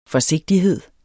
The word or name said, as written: forsigtighed